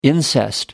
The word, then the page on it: incest: American English